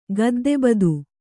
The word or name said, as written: ಗದ್ದೆಬದು